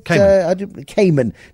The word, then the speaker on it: Kamen: Leonard in Boxburg has got some insights into Dean, how do you pronounce it? Kamen. Dean Kamen.